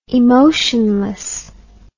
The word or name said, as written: emotionlessness